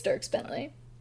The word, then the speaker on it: Dierks Bentley: Dierks Bentley? I think it's Dierks Bentley.